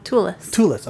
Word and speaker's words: Thewlis: So to continue on, the production was a complete nightmare for British actor David Tullis. I feel like I'm saying his name wrong. T-H-E-W-L-I-S. – Tullis. – Okay, thank you.